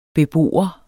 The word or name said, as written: BEBOERNE